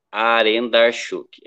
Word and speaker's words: Arendarchuk: Eu vou falar bem, é bem facinho. Arendarchuk. É como tá escrito mesmo.